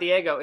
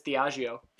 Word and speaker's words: Diageo: And it's not Diego, it's Diageo. – Excuse me, I did know it was Diageo. – Yeah. Yes. That I realized after typing it so many times in our descriptions or for tags. – Yeah. – But I was like, that's not Diego. – Yeah. – It is Diageo. – It's like, got the little thing on the A. – Well, yeah, well, just the spelling, because it's like D-I-A-G-E-O, so Diageo.